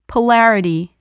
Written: polarity

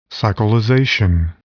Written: cyclization

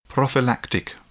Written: prophylactic

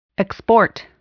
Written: export

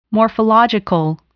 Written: morphological

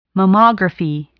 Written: mammography

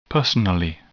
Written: personally

